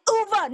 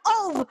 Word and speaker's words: Ove: It's Uwe, not Ove.